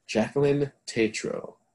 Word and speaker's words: Jacqueline Tetrault: I am pleased to introduce Jacqueline Tetrault. Did I say that right? – Yes, you got it.